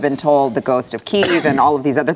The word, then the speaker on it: Kyiv: I'm actually saying the Ukrainian pronunciation of the capital, Kiev, is actually the Russian pronunciation of that city.